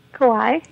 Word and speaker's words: Kauai: how do you say it, Kauai? – Kauai, yeah.